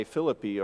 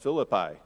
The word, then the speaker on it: Philippi: And this letter is written to a group of the people that he established a church in modern-day Philippi, or it's pronounced Philippi sometimes.